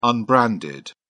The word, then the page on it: unbranded: British English